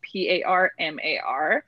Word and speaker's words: Parmar: Uh, last name is spelled P A R M A R, and um, I have a lot of my Instagram.